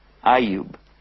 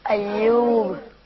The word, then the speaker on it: Ayyub: Ayyub. And in English they call him Job. But they spell it J-O-B, which looks like job. But his name is Ayyub. Can you say Ayyub? – Ayyub.